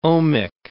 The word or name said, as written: ohmic